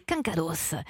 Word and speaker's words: quincados: Moi, j'ai choisi de prononcer le S. On se posait la question avec Eva, est-ce qu'on prononce le S ou pas ? Mais moi, je trouve que c'est plus sympa. Quincados.